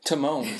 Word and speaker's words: Timon: For Timba, did I say that right? Timon. Sorry, I just combined both their names.